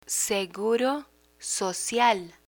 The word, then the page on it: seguro social: spanish